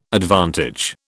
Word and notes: advantage: British English

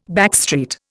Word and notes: backstreet: Indian

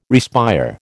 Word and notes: respire: American English

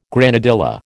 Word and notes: granadilla: American English

